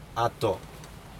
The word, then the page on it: ato: Aneityum